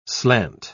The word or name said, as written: slant